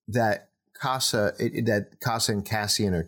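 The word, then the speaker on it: Kassa: His real name is what? Cass. It's similar Casa. – To Cassa. – Casa. – It's Cassa. Yeah.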